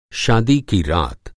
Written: शादी

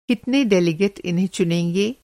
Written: डेलिगेट

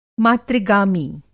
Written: मातृ-गामी

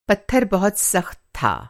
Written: सख़्त

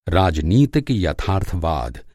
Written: यथार्थवाद

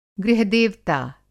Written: गृह-देवता

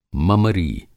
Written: ममरी

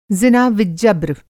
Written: ज़िनाविज्जब्र

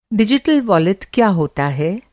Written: डिजिटल वॉलेट

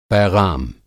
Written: पैग़ाम

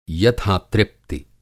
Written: यथा-तृप्ति

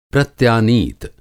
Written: प्रत्यानीत

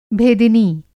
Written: भेदिनी

